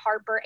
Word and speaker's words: Harper: So it's at Lindsay, LYNDSEY, Harper, HARPER, MD.